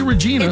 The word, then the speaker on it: Regina: R-E-G-I-N-A. – Oh. – And you just want to say Regina.